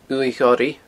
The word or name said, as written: uaimh ghoraidh